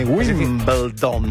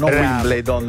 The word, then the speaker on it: Wimbledon: Uno degli episodi più votati, scelto da me, è stata proprio la vittoria di Wimbledon. Senti come ho detto bene, Wimbledon, non Wimbledon.